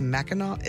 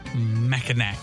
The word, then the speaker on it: Mackinac: I believe it's pronounced Mackinac. Is it? – I don't know. – It's spelled Mackinac. – Mackinac.